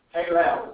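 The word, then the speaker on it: Halal (הילל): Halal is really how it's pronounced.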